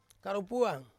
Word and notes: karupuang: Ansus

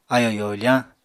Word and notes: ayò yoofañ: apatani

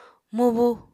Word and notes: mubu: Apatani

